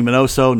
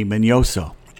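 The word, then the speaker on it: Miñoso: All my life it's Minnie Mignoso, now it's Minnie Mignoso.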